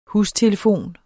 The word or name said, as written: hustelefon